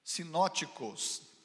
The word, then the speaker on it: sinóticos: João não é, no entanto, um dos evangelhos chamados sinótipos. Você sabe que Mateus, Marcos e Lucas são chamados sinótipos. Sinóticos, tipos, não, ticos.